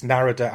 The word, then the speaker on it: Narada: It's Narada, as in Florida, because it was always Narada Michael Wilder.